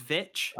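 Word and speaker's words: VVitch: It's witch, but it's pronounced vitch.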